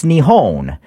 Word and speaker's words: Nihon: Japan doesn't call itself Japan. It's Nihon.